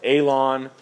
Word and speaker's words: Aijalon: You can see on the map, A-I-J-A-L-O-N, okay? Pronounced Aalon Valley, okay?